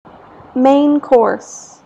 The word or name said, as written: main course